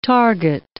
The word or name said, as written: target